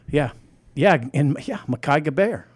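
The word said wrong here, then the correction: Gbayor: Did I say it right? – G silent. – Let's just keep the streak of simple names going here. – Makai Bayer. – Bayer. – Okay. – Like the aspirin.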